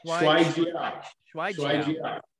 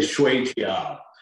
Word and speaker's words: Shuai Jiao: You said it correctly, believe it or not. – Shuai Jiao. Okay. – Yes, you said it better than many Kung Fu and Tai Chi instructors in the United States who are Americans. Do not pronounce it correctly. They say Shui Jiao, or they bastardize it.